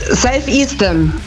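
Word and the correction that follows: Self Esteem: Ja, die Aussprache ist ein bisschen... – Steam, ist Steam. – Ja, ist richtig. – Ja, Self-Esteam nehmen wir.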